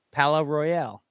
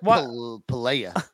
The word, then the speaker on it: Palaye Royale: Pala Royale. – Is that how you say it? – Mm-hmm. – Thank God you're here. I would have said Palea.